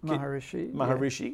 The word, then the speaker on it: Maharishi: the Maharishi. – Maharishi. – Yeah. Well, that was relatively close.